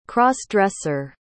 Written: cross-dresser

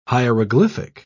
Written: hieroglyphic

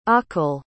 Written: aqil

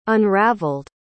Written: unraveled

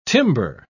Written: timbre

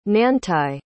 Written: nantai

